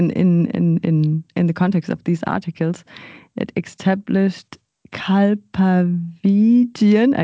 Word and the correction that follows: Kalpavigyan: I can't even pronounce it. I'm sorry. As the template for research Again. – in Indian future fiction. Can you talk about this? – Yeah, sure. Yeah, the word is Kalpavigyan.